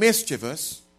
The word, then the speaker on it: mischievous: And in fact, sin is so devious and mischievous. Mischievous is the right pronunciation, by the way, not mischievous. Mischievous.